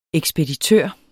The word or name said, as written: ekspeditør